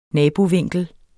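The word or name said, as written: nabosprog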